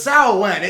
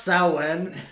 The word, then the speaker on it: Samhain: Yeah, I said it, so it's Samhain. It's spelled Samhain, and I didn't want to be like one of those like New Age pagan assholes, but it is pronounced Samhain.